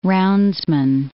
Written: roundsman

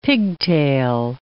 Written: pigtail

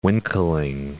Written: winkling

